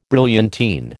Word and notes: brilliantine: American English

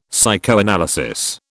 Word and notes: psychoanalysis: British English

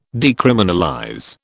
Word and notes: decriminalize: British English

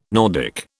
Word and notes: Nordic: British English

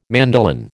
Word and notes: mandolin: American English

